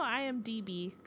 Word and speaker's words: IMDB: On their version of IMBD, or I always feel like I say that wrong. Yeah, no, IMDB, excuse me.